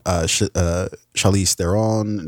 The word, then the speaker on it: Charlize Theron: Bombshell, which comes out this Friday, starring Charlize Theron, Nicole Kidman, Margot Robbie, John Lithgow.